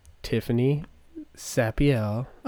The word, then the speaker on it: Tiffany Sapiel: Because I have Tiffany Sapiel. – Oh, good. You pronounced it right.